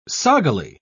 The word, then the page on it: soggily: British English